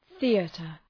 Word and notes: theater: ‘ɵi:ətər

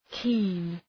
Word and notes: keen: ki:n

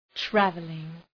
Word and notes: travelling: ‘trævəlıŋ

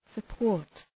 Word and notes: support: sə’pɔ:rt

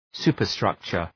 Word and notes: superstructure: ‘su:pər,strʌktʃər

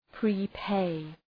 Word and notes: prepay: prı’peı

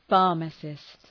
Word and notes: pharmacist: ‘fɑ:rməsıst

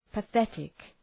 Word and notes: pathetic: pə’ɵetık

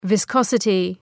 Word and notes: viscosity: vı’skɒsətı

